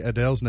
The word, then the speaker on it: Adele: No, apparently, the correct way to say Adele's name is Adele. – No, it's Adele. – As opposed to the more popular pronunciation of Adele. – Uh-huh. – Now, if you have her British accent, I can see where it would come out, Adele. But... – Well, see, what's interesting is my grandfather, who was British, it was Adele. – It was emphasis. It was heavy on the first syllable.